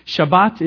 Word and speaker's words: Shabbat: Shabbat is how a Jew would pronounce this word.